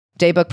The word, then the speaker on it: Daybook: Daybook pronounced like day bed."